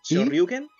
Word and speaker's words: Shoryuken: Ya no lo diga mal. Usted no lo diga, como decía el profesor Bandera. – Pronúncelo bien, como corresponde. – ¿Cómo sería entonces? – Papouken, Shoryuken y Tatsumaki Senpukiano.